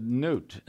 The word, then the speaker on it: Knute: And I often say Lars because they could spell that or come close, and it's easy, but I like my name, so not long ago I said Newt, and the girl looked at me, and I said, K-N-U-T-E.